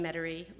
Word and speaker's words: Metairie: Ellen DeGeneres, well-known and beloved female comic and Showtime's funniest person in America in 1984, was born January 26, 1958, in Metairie, Louisiana.